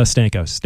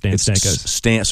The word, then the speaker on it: Stankos: Stan, help me with the pronunciation of your last name. – It's a Stankos. Stankos. – So Stan rhymes twice. There's Stan and Stan.